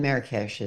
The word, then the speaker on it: Marrakesh: In Marrakesh is the proper pronunciation.